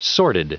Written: sordid

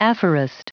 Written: aphorist

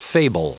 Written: fable

